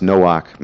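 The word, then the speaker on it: Noach (נח): Like, for instance, you know, Noah, which in Hebrew would be translated or be pronounced Noach, means rest.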